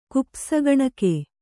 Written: ಕುಪ್ಪಸಗಣಿಕೆ

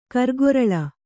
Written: ಕಾರುಗೊರಲ

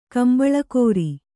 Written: ಕಂಬಳಕೋರಿ

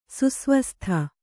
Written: ಸುಸ್ವಸ್ಥ

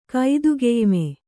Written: ಕೈದುಗೆಯ್ಮೆ

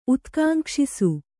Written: ಉತ್ಕಾಂಕ್ಷಿಸು

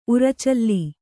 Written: ಉರಚಲ್ಲಿ

